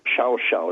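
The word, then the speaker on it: Llao Llao: There's this magnificent resort called Shao Shao. It's spelled LLAO-LLAO. How you get Shao Shao out of that is anybody's guess, but that's what it's called down there.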